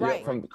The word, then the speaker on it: Reich: Well, listen, one of our producers, JP, just texted me and said that Frank Reich, am I saying his name right? Rich? Reich? – Frank Reich? – Yep.